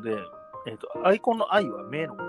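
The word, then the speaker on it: 眼魂: うんうんうん、だから、えっ、と、ガンコンって書いてるんですけど、読み方はアイコンっていうことになりますね。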